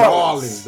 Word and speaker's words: New Orleans: New Orleans. – New Orleans, baby.